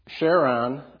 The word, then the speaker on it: Charon: Pluto's moon, Charon, I believe it's pronounced, is roughly half the size of Pluto.